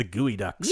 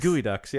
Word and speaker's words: geoducks: Is it D-U-C-K-S? – D-U-C-K-S, but it's spelled G-E-O-D-U-C-K-S. – I think I knew that. – It's spelled Geoducks. – Geoducks? – But it's pronounced Gooey Ducks? – Gooey Ducks, yeah.